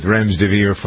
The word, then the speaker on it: remdesivir: It's not remdesivir. It's remdesivir. Like, spell it out phonetically, Mr. Former English teacher.